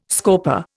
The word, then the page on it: scorper: Indian